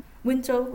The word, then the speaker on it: Wenzhou: Alors vous êtes surtout concentré sur l'étude de personnes originaires de Wenzhou, on prononce comme ça. – Wenzhou, oui.